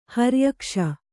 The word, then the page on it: ಹರಿಯಕ್ಷ: kannada